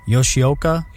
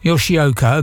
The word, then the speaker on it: Yoshioka: Noshioka, is that right? Say it again. – Yoshioka. – Yoshioka, okay, thank you.